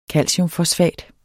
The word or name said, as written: calciumfosfat